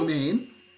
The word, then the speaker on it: Romijn: He's married to Rebecca Romijn. Is that how you say it? – Romijn. Yeah, Romijn.